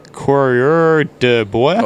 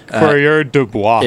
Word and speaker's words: coureur de bois: Coureur de bois. – Oh, you just killed it. – Coureur de bois. – It's coureur de bois.